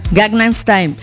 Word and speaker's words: Gangnam Style: Ah, a ver, quiere decir la de Ganga, la de, es que dice la canción de Opa Ganga Style, pero no se llama así, ¿o sí? Gangnam Style.